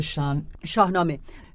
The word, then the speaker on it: Shahnameh: That's the Shahnameh.